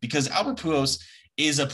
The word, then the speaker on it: Pujols: Pujols.